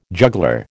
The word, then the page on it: juggler: American English